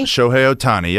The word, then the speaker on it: Shohei Ohtani: Shohei Ohtani? – Shohei Ohtani, yep, got it.